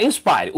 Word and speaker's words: Inspire: Aqui, ó, o, o, o DJI Inspire. É Inspire mesmo? Eu estou falando errado. É Inspire.